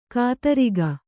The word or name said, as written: ಖದಿರಕ